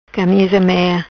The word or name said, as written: Camisa Mea